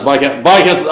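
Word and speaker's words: viscount: So, barons, earls, dukes, viscounts, looks like this count, but it's pronounced viscounts, all that means nothing here.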